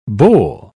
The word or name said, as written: bowl